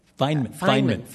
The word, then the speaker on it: Feynman: Well, you also talk about Feynman. Is that how you pronounce it? The scientist Feynman? – Feynman. Sorry, yeah, Feynman.